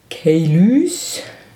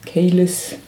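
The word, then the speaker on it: Caylus: Ja, also in dem Jahr 2006 ging der Sonderpreis Komplexes Spiel an Kailus. Kailus. Kailus Kaylis. Also eigentlich müsste man es französisch aussprechen, denn der Autor des Spieles ist Franzose, der Verlag ist französisch und Kaylis ist eine französische Stadt und oder Region.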